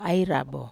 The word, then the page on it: ai rabo: Wamesa